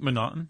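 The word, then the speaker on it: M'Naghten: I think Manotton. – Manotton. – It's M apostrophe Naughton. And it looks like it should be McNaughton, but they left the C out and replaced it with an apostrophe.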